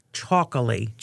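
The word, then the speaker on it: Txakoli: It's T-X-A-K-O-L-I-E. Choccoli. – Choccoli. – Choccoli. So, Choccoli. As I understand it, the what is it? The where you step on the syllable, that part is on the very end. So li is the big pronunciation. So it's Choccoli.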